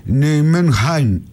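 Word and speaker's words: Nijmegen: Η Ολλανδική όμως, λέει, προφορά είναι Νιουμενχάιν. Είναι κάπως βαθύ το χ. Δεν μπορώ να το πετύχω.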